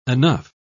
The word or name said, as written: enough